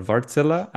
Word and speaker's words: Wärtsilä: And joining me today are Chris Groves and Michal Syed Navid from Wärtsilä, or Wärtsilä, as it's pronounced in its native language.